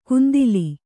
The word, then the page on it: ಕುಂದಿಲಿ: kundili